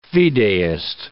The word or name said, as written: fideist